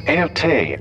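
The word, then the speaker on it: Erté: It's an original Erte. The late Dr. Thompson was very fond of his work. – It's nice. – Russian-born painter Roman de Tirtov called himself Erte after the French pronunciation of his initials.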